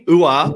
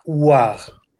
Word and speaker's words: Aouar: How do I say his name then? – Uah. – Uah. Uah. – Uah. – Uah. – There you go.